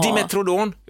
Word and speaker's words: Dimetrodon: Dimetrodon, uttalade rätt.